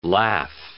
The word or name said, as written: laugh